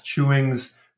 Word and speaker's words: Chewings: So it's spelled like chewing gum with an S, Chewings Red Fescue.